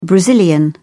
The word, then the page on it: brazilian: British English